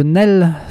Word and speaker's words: Nesles: La suite, eh bien, on revient en France à Paris avec un album de Nel. Ça s'écrit N-E-S-L-E-S.